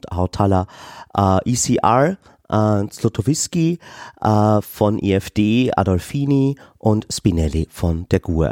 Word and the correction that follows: Adinolfi: Das ist nicht Adolfini, sondern Adinolfi.